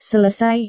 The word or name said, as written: selesai